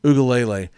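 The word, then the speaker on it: Uiagalelei: Ugalele. I've heard a couple of guys throw like, it was like seven syllables. I was like, that is not right.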